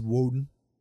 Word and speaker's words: Wojdan: Yeah, and if you want to follow me on any social media, stuff like that, my handle is Edward Woden at anywhere there, or LOTB0. Again, my last name is spelled W-O-J-D-A-N. It is a mouthful. It's Woden.